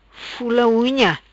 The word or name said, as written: Fula Unha